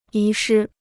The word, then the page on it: 遗失: yí shī